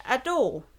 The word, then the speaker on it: Adou: Denn der Titel heißt Ado. Geschrieben wird er aber Ado-U. Also A-D-O-U. Spricht man aber im Japanischen nicht aus, sondern O-U ist ein langes U.